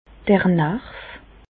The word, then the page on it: d'r Narv: Bas-Rhin; Reichshoffen